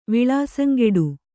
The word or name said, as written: ವಿಳಾಸಂಗೆಡು